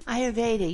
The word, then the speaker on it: Ayurveda: And I was doing my research, and what exactly, for the people who don't know, Ayurveda, did I pronounce that correctly? – Ayurveda, you did.